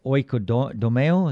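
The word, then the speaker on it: oikodomeo (οἰκοδομέω): The word is, let's see, in Greek, it's oikodomeo. Is that how you say it? – Yep.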